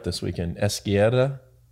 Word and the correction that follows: izquierda: No, izquierda.